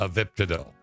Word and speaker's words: Avibtadil: Avibtadil. – I pronounced it right. I would just like to point that out.